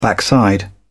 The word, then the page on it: backside: British English